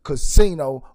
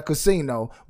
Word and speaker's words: Ca$ino: And I am the creator, the producer, and the host, Casino Roulette. You see my name right there, Casino, but it's spelled CA$INORoulette.